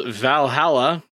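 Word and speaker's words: VA-11 HALL-A: Um, it's called Valhalla, and I'll spell this one out for mm. you because it is spelled weird. Yeah, it's it's the bar game. Yeah, it's a bar game. Um, so the spelling is V A hyphen one one space Hall hyphen A, because uh, that is the actual physical location of where it like exists in like a mega building in the future or whatever.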